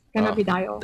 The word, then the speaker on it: cannabidiol: Is that how do you pronounce that, Doc? Is it cannabidiol? – It's cannabinoid. Okay. Now you got me all confused, but cannabidiol. – Yes. There we go. Oh, I said it right.